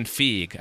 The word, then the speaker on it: Feige: Like Star Trek needs a Kevin Feige. I think that's how, is that how you pronounce it, Brad? – Yeah.